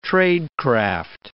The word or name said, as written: tradecraft